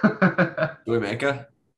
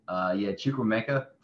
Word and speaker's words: Chukwuemeka: How do you say this one? – Chukwuemeka. – Oh no, it's gonna take some listening getting used to. Chukwuemeka probably sounds about right.